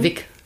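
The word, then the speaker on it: Vick: Also, wir haben hier den Fick Neo. Ja. Und wie wird der richtig ausgesprochen? Wick. Wick. Aber mit V. V-I-C-K Neo.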